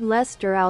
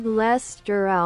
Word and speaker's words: Leicester: Lord Leicester aus Manchester. Lord Leicester aus Manchester. – War hier doch, und in Deutsch? – Lord Leicester aus Manchester.